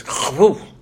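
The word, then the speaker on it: Chava: It was Chavu. That's how the Hebrews pronounce it.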